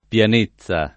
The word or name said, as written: pianezza